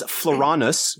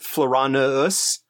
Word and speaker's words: Floranus: We got the one that he said we missed was Floranus, which is how I pronounce it. It does have a double, as in double, as in two of them. U, not a W, a double U. And so I would also accept Floranus, although that extra syllable really strikes me as awkward when I'm saying it.